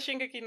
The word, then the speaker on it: Shingeki: Shigenki no Kyojin. Sí, opening 2 de Shingeki no Kyojin. Ah, opening de la segunda temporada. Opening 1 de la segunda temporada de Shingeki no Kyojin.